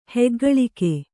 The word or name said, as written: ಹಿಗ್ಗಲಿಕೆ